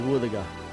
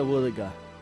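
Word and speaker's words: Wuthuga: Our local Mulgana word today is Woothaga. What is a Woothaga? Woothaga is a dugong.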